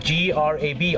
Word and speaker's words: grab: คำว่า G R A B ออกเสียง grab คล้ายๆกับกินแกร็บเนี่ยนะครับต้องใส่ตัว G ตัว R แล้วก็ลากยาว grab